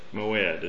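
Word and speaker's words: mo'ed: Moed is how you pronounce it. M-O-W-E-D.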